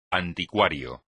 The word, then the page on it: anticuario: espagnol